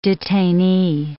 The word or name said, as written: detainee